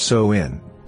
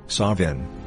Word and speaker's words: Samhain: Samhain, pronounced So-in, Sawin, or Samhain, means end of summer and is the third and final harvest.